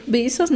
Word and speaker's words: Bezos: O Jeff Bezos, no sé cómo se pronuncia. – No, está bien.